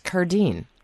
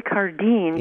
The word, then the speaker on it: Cardin: Oh yeah, it looks like Cardin, but it's Cardine. – Joy Cardine.